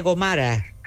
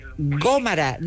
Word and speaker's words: Gómara: Con el problema de los acentos, hay una avenida en Sevilla, yo conozco la Casa Condal de Soria, que se llama, aquí le dicen, Avenida López de Gomara, y es Avenida de López con acento, Gomara, con acento en la O de Go. Pues nada, no hay tu tía, estoy por llamar a los autobuses, que es donde me entré, y además lo he visto por todas las calles. No, señora, Gómara, no Gomara.